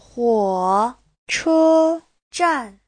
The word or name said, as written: huo3che1zhan4